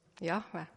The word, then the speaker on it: Jahve: Jahve. Ja. Jahve õigesti hääldades ei liigu ei keel ega huuled.